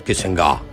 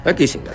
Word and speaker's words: Kissinger: Ah, bueno, sí, si querés acentuarlo un poquito mejor, Kissinger. Kissinger.